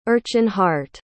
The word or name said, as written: erchanhardt